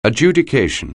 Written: adjudication

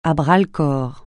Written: à bras-le-corps

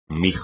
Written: mijo

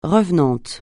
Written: revenante

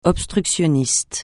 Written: obstructionniste

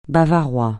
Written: bavarois

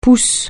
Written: pousse

